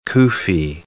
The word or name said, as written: kufi